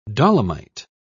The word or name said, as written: dolomite